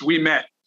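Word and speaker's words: Ouimet: How do you say his name? Francis? – Francis, we met. – We met. – Yeah, like, you and I were down the street and then we met.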